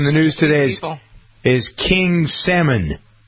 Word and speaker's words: Salman: S-A-L-M-A-N is how you pronounce the name. Salmon. – Salmon. – Salmon. – Like Salmon Rushdie.